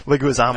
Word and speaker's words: Leguizamo: John Leguizamo, or however you pronounce that. And uh, not like that. Leguizamo. Not like sorry.